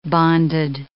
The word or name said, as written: bonded